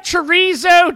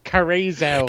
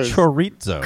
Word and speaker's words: chorizo: Can I get that chorizo Cheeto burrito? – Well, I think Barry pronounces it carrizo. – Carrizo. – Chorizo. – Chorizo.